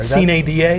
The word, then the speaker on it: sine die: I always pronounce it sine die. I think there are some different pronunciations of the Latin there. – Sine die is, I think, the appropriate Latin pronunciation.